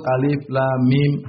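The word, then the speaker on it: alif lam mim (الم): Seperti alif lam mim, jangan dibaca alam, tapi alif lam mim. Alif lam mim.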